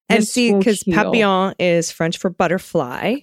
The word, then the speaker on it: Papillon: Papillon. – Papillon. Thank you. I knew that the LL was not a hard L, but I couldn't remember.